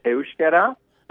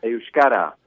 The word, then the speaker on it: euskera: It's called euskera. Did I pronounce that correctly? – Hard word, isn't it? It depends. Sometimes euskera, sometimes euskara. It depends on the region of the Basque country you're in.